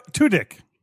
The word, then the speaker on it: Tudyk: How do you say that? – Tudyk. – Tudyk. – Yeah, it's... – That doesn't sound right. – It's actually correct.